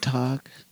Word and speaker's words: talk: No, it's like silent L because you don't like talk. – Yeah, yeah. – That's a good point. That's a good point. Talk. You only hear the K really in T-A-L-K.